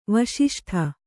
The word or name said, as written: ವಿಶಿಷ್ಟ